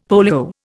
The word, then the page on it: polio: Indian